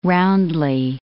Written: roundly

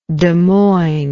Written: Des Moines

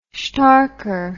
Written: shtarker

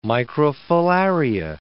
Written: microfilaria

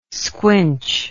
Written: squinch

